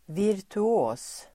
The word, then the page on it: virtuos: [vir_tu'å:s]